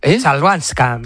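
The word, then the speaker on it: Chwalińska: Joder, ¿cómo se dice la es complicada, ¿eh? Chabalinska. – Chalbanska. – Chalbanska. – Maya Chalbanska.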